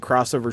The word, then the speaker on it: X-over: and I think it's supposed to be pronounced like cross, but it's spelled X-over, like it's an X-Men series from the 90s.